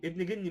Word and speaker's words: ابن جني: يا فيصل سيبويه، وابن جني، ابن جني، ابن جني مش ابن جني، هو ابن جني ينطق هكذا لأنه اسم فارسي.